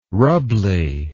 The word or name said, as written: rubbly